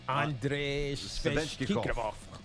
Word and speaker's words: Svechnikov: Andrei Svechnikov.